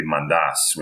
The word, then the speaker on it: Mantas: And my last name, Mantas, is more of a traditional Greek last name. It's really Mantas when you pronounce it in Greek.